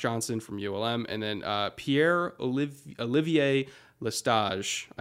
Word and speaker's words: Pierre-Olivier Lestage: Now, so if I was going to do like full on French pronunciation, Pierre-Olivier Lestage.